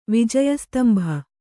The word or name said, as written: ವಿಜಯಸ್ತಂಭ